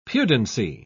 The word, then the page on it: pudency: British English